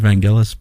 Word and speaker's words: Vangelis: I believe it's actually pronounced Vangelis.